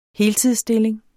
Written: heltidsstilling

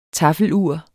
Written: taffelur

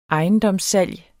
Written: ejendomssalg

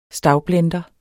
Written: STAVBLENDERE

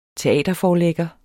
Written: teaterforlægger

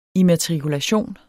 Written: immatrikulation

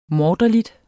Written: morderlig

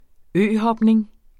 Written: øhopning

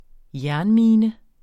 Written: jernmine

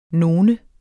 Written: none

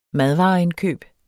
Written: madvareindkøb

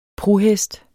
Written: PRUHESTENES

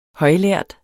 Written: højlærd

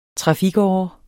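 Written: TRAFIKÅRES

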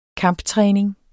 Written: kamptræning

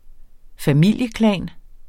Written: familieklan

